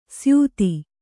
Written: ಸ್ಯೂತಿ

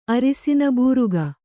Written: ಅರಸಿನ ಬೂರಗ

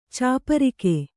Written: ಚಪ್ಪರಿಕೆ

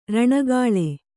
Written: ಋಣಗೂಳು

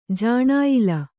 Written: ಜಾಣಾಯಿಲ

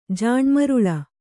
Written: ಜಾಣ್ಮರುಳ

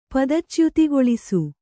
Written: ಪದಚ್ಯುತಿಗೊಳಿಸು

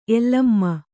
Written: ಎಲಮ

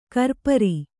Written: ಕರಪುರಿ

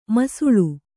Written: ಮಾಸಾಳು

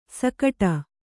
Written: ಸೈಗುಡು

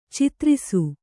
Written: ಚದುರಿಸು

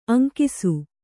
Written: ಅಂಕಿಸು

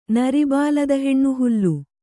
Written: ನರಿಬಾಲದಹೆಣ್ಣುಹುಲ್ಲು

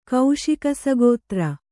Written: ಕೌಶಿಕಸಗೋತ್ರ

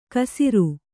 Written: ಕಸರು

